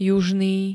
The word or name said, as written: južný